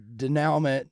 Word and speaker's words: denouement: denouement. – Denouement. – Thank you. – I really should have written it out or started, bitch, pronounce it in French.